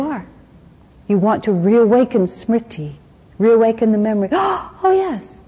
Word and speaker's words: Smriti: Smriti is the wonderful Sanskrit word for it.